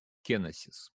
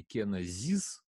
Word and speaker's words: кеносис: Вот, э, значит, настоящее правильное ударение, как видите, оно копирует э, греческий оригинал и ударение на первый звук «ке» – «кеносис». Вот, есть вариация произносить это «кеносис» или «кенозис», то есть как будет этот звук, может быть, а, более звонким. Вот. Но это одно и то же слово.